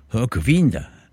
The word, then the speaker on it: Hörkvinda: And I'm reliably informed by our Swedish student who's staying with us at the moment that the track is called Hörkvinda. And one of my strange pronunciations, but I'm told that's how you say it.